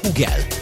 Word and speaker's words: Hugel: Francia nyelvtelten Hugel, amúgy pedig nagyon durva, de Hugel a helyes kiejtés.